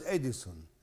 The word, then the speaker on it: إديسون: المخترع توماس إديسون، توماس بالتاء وإديسون بكسر الهمزة.